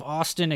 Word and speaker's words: Oston: Now it's spelled like O-S-T-O-N. – So like Boston. – With no B, yeah.